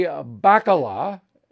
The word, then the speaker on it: Bacala: And then I contrast you to Bobby Bacala.